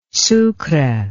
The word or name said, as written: sucre